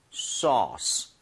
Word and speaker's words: sauce: AU라는 스펠링 보이시죠? – 예. – AU나 AW 스펠링이 나오면 O보다 조금만 입모양을 벌려준다. 아. 이 느낌. 그래서 소스보다는요. sauce. sauce. 살짝만 벌리시면 되거든요.